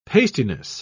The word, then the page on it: pastiness: British English